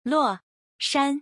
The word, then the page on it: 洛山: Luo Shan